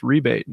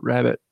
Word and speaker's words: rebate: It's pronounced rebate, not rabbit.